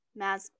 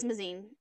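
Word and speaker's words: Mazeen: Hawk kills the Duke by impaling him, and then she kills Lord Mazakine. – No, I'm pronouncing it wrong. I know I am. – I don't remember it because, like, I didn't. – Mazine? – I think it is Mazine. It's M-A-Z-E-E-N.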